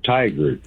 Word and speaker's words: Tigard: it almost sounds like tiger, but it's tiger. It's like T-I-G-A-R-D.